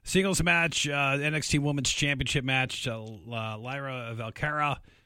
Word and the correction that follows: Valkyria: I know I'm going to butcher that. – Lyra Valkyria. – Yeah, Valkyria.